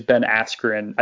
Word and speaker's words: Askren: And I mispronounced his name. It is Ben Askren. I think the announcers during the fight last night were calling him Ben Askren, though.